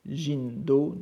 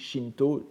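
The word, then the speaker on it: Jindo (神道): Jindo, n'est-ce pas, à prononcer la bouddhique, Shinto, Jindo, auprès de Butsudo.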